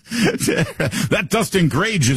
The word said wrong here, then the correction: Grage: is doing a great job, and probably half my colleagues are like, who are you talking about? It's Greggy.